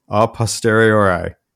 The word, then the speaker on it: a posteriori: So the other fancy term that's associated with this is a posteriori.